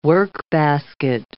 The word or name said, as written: workbasket